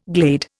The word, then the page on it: glade: Indian